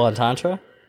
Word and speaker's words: entendre: What, that was a double entendre? – Yeah. – Entendre. – Entendre? – Yeah. – Double entendre? – Yeah, I said it right.